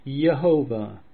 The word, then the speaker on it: Jehovah: The four letters form a word pronounced Jehovah,